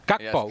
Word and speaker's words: Gakpo: Man, I've been pronouncing that wrong. – Gakpo. – Yes.